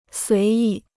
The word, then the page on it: 随意: suí yì